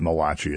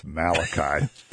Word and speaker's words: Malachi: Because, you know, the prophecies at the very end of the Old Testament in the book of Malachi, that's not Malachi, it's Malachi.